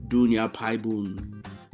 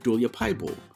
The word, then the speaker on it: Dulia Paibul: Today, before I hit record on this interview, I had to ask Amarit how to pronounce his name, because in Thai, his last name would be pronounced something like Dunya Paibun, although I think I have the tones wrong there. But he's an American, and he pronounces it Dulia Paibul,